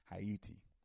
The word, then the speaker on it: Ayiti: That's how you pronounce Haiti. It's pronounced Haiti. A Y I T I dot org.